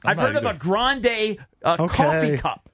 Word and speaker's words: Grande: I don't know if it's Grande. I get confused. – Well, you've heard it. – Grande still has an incredulous look on his face. – You've heard it pronounced Grande a billion times.